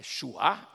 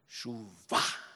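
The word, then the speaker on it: chuvá (שׁוּב): É chuá? Não. Chuvá. Olha, a palavra para arrependimento na Bíblia é chuvá. Se você quer que chuva, você chuvá.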